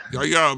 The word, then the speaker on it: Yahya: I think you're overthinking that. I think it's just Yahya. – Yahya? – I think the H is silent, like Yahya. – Yahya Abdul-Mateen II and Chris Hemsworth, who are in unknown roles, who will also be cast in this movie.